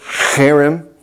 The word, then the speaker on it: cherem: And that H is pronounced like you're clearing your throat, cherem.